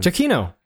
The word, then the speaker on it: Giacchino: Giacchino. Michael Giacchino. – That's how you pronounce his name? – Yes, that's how you pronounce it.